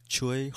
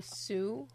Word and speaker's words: Tsui: I can't pronounce his first name, T-S-U-I, Hark. I don't know if you can pronounce that. I'm thinking Chui Hark. – Probably Sue.